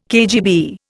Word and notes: KGB: Indian

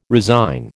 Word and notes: resign: American English

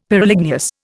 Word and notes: Pyroligneous: Indian

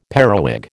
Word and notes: periwig: American English

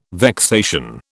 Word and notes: vexation: British English

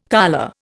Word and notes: colure: Indian

